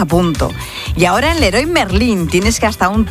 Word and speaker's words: Leroy Merlin: Mensajito, ¿cómo se dice? ¿Leroy Merlin o Leroy Merlin? – Leroy Merlin. – Amigas, son franceses. – No, Leroy Merlin. Merlin, Leroy Merlin, toda la vida.